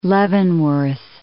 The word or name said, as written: Leavenworth